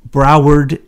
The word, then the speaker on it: Broward: Well, yes, he did go crazy because he was the same old usual suspect in Broward County.